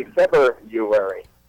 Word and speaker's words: February: Phonetically, it would be February. – So if we did it absolutely phonetically, it would be February. – February, F-E-B-R-U-A-R-Y, February.